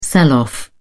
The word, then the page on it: sell off: British English